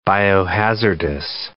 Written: biohazardous

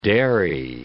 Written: dairy

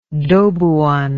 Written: dobuan

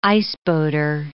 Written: iceboater

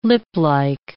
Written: liplike

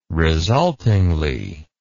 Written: resultingly

